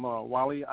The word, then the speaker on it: Wali: Yes, I'm Wali Ayub Bilal. I'm the Imam out of the Philadelphia area. My name is spelt W-A-L-I.